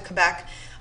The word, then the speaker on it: Quebec: I know in English we say Quebec. Did I say that right? Quebec? Quebec.